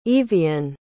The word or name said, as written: yvian